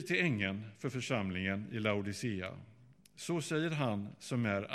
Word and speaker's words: Laodicea: Och den 7:e församlingen, det är den församlingen i Laodicea, eller Laodikeia, hur den uttalas. Jag säger Laodicea.